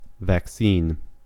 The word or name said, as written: vaccine